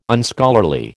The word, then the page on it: Unscholarly: American English